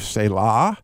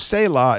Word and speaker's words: Selah: So you hear that H sound at the end of Selah, and that letter Hey is simply almost like you just see a little guy standing there with his hand up.